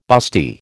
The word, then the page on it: busty: American English